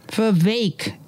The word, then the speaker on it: Vivek: Vivek, it rhymes with cake.